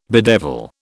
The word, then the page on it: bedevil: British English